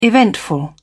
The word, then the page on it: eventful: British English